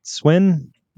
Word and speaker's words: Swen: I think it's Swen? Did I say that right? – Yeah, S-W-E-N, yeah.